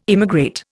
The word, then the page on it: immigrate: Indian